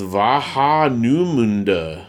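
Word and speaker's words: Warnemünde: Oh, man, I'm messing that up. Warnemund. Oh, pronounced Vahanumunda. Oh, I see. Oh, man, I butchered that.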